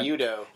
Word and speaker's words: MUTO: That is a Japanese pronunciation of the acronym. They could have very well, you know, did the Americanized Anglican. – Like Muto. – Yeah, Mutos. That's what we would have called it. – It's a Muto. – Yeah, and they kept pronouncing it Muto, which I thought was pretty cool. It was kind of a nice nod.